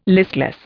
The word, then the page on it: listless: Indian